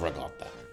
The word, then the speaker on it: ricotta: Yeah, I love ricotta. And yes, that is how you pronounce it.